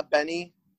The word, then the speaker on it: Benee: Is it Benee? – Yeah, I think yeah, that's the name. Yeah, Benee.